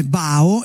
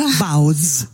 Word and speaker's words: baozi: scusami, tu hai detto, io li conoscevo come bao, invece la pronuncia baoz.